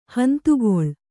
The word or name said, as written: ಹಿಂದುಗಳೆ